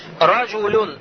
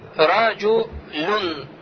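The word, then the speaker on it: Раджу люн (رجلٌ): Например, я говорю, смотрите слово. Раджу люн. Раджу люн. Слышите, да, на одной сукун? Однако мы же, когда раджу улюн пишем, не видим же этот нун с сукуном.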